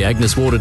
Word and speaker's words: Agnes Water: Heading down to Agnes Waters. Right. It's pronounced Agnes Water.